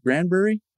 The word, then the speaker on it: Granbury: So did I pronounce that right? Granbury? – Yeah, it is Granbury, yeah.